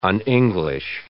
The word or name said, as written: un-English